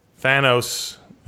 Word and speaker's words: Thanos: As finally, Thanos, who we now know is the pronunciation, arrives after first seeing him at the very end of the original Joss Whedon Avengers film.